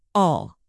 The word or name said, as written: all